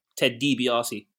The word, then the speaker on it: DiBiase: I mean, you know, Ted DiBiase is DiBiase. Say his name properly, innit? – Sorry? – Ted DiBiase.